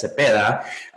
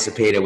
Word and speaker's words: Zepeda: So in Spanish, my name is pronounced Elias Cepeda, um, but uh, here in the U.S., it's probably a lot easier for you know for people to you know refer to me as Elias Zapata, which is I guess the American way of saying my name, but it's a Hispanic uh, origin name.